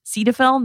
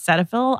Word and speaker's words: Cetaphil: First and foremost, that the brand is pronounced Cetaphil, not Cetaphil.